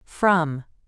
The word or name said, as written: from